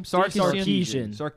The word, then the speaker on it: Sarkisian: Sarkisian.